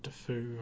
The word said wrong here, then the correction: Duffy: I can't remember how exactly to say it. Duffy is how you pronounce it.